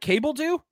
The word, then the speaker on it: Cabeldue: Bryce Cabeldu. Did I say that right? Cabeldu? C-A-B-E-L-D-U-E.